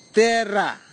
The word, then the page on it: tera: Kwamera